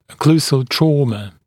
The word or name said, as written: occlusal trauma